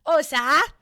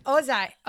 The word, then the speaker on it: Ohza: How do we say that? – Oza. – Okay. – Oza. O-H-Z-A. Oza.